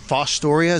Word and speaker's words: Fostoria: Fostoria, is that how you say it? – Yeah, that's it.